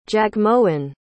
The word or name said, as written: jagmohan